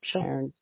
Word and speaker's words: Cairns: Cairns, whatever.